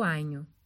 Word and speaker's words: Agno: Cornedo Vicentino é um município italiano localizado no Vale do Anho. Se fala Anho, mas se escreve Agno.